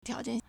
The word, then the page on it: 条件: tiáojiàn